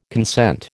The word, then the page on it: consent: American English